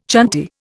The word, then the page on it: chanty: Indian